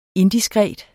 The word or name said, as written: indiskret